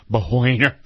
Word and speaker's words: Boehner: So the president had a little meeting yesterday with the boner and Mitch McChinless. – The boner. – Boehner. – Boehner. I'm trying to pronounce it properly in its original.